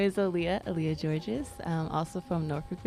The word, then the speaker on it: Aaliyah: Aaliyah, like the singer.